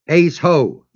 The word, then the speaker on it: eisho: The text again is eisho, E-I-S-H-O, long O.